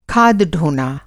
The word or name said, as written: ढोना